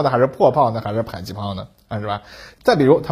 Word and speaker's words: 迫击炮: 这两个特点呢决定了这种火炮可以在距离目标较近的地方发射，炮弹呢可以越过各种障碍物落到敌人的头上，这就是它为什么被叫做迫击炮了啊，就是啊迫近敌人的地方攻击它的意思。只不过呢后来约定俗成，大家把这个词儿啊读成了迫击炮，成了一个专有名词，而我国台湾地区呢至今仍将它读成。迫击炮啊，并且进一步简化成了破炮啊。